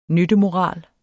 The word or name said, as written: nyttemoral